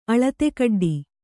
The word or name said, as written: ಅಳತೆಕಡ್ಡಿ